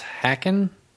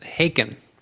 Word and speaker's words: Haken: I always thought the band was Hacken. – Hacken. – But everybody at the show pronounced it Haken.